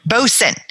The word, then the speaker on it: boatswain: Bosun.